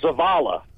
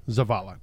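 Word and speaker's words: Zavalla: We go to next from Zavalia, Texas. I hope I pronounced that town name correct. What's bugging you tonight? – Hi, good evening. It's Zavala. – Zavala, gotcha.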